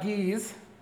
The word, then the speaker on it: Guise: The family of Guise, and that is how you pronounce it.